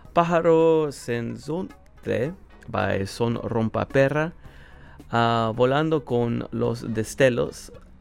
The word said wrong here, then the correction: Destellos: Volando con los Destellos.